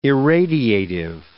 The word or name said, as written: irradiative